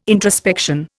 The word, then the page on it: introspection: Indian